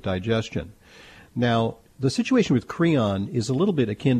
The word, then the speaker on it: Creon: Okay, so yeah, Creon, for a listening audience, I mean, rhymes with Freon, but it's not antifreeze.